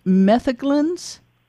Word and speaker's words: metheglins: Is it pronounced metheglins? – Correct.